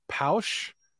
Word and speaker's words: Pausch: One book is called The Last Lecture, written by a guy named, the way you pronounce his name is Randy Pausch.